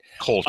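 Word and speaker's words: Kölsch: I hope I'm pronouncing Kolsch right. – Kolsch, It's an ale brewed with blueberries and blackberries.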